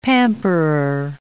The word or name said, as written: pamperer